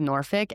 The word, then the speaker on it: Norfolk: There are people who say Norfolk, or some people say Norfolk, which that's how I, you know, immediately wanted to say it just by how it's spelled. But I think for the most part, most of the coverage that I've seen, people are saying Norfolk. So I'm going to go with Norfolk, and I'm sorry if that is wrong in your mind.